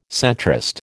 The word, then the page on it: centrist: American English